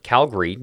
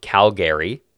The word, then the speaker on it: Calgary: And I start off the presentation by saying, explaining that you pronounce it Calgary, not Calgary.